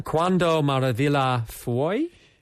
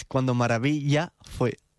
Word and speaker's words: Cuando Maravilla Fue: Cuando Maravilla Fue? – Yeah, almost there. It's Cuando Maravilla Fue. – Oh, not too far away.